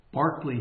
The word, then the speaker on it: Berkeley: Berkeley. Barkley, he wanted his name pronounced. So I'll try to do that.